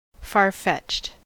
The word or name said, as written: far-fetched